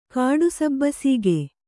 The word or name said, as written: ಕಾಡಸಬ್ಬಸೀಗೆ